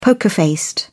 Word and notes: poker faced: British English

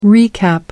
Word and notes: recap: American English

